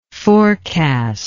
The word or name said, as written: forecaster